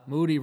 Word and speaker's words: Moody: And his guys, Moody rhymes with duty.